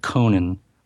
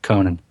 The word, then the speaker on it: Conan: And they're officially pronouncing it Conan. Multiple characters in the different trailers, Conan.